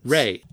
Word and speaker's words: Ralph: One question is it Ray Fiennes or Ralph Fiennes? – Ray. – But it's spelled Ralph.